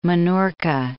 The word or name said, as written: Minorca